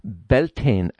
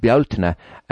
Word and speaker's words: Beltane: And it is associated with the festival of Beltane, as it's pronounced in the US, but Beltane as we know it in Ireland.